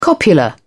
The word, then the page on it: copula: British English